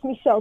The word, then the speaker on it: Michal: It's Michelle Schlesinger, even though it's spelled like Michael. – Okay, so because I got it spelled M-I-C-H-A-L.